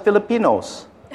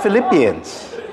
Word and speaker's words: Philippians: I thought it was Filipinos. She goes, Dad, that's Philippians.